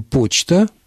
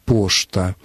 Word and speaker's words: почта: Мы говорим «почта», «почтовый». В старомосковском варианте русского литературного произношения говорили «поштовый», «пошта».